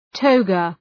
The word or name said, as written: toga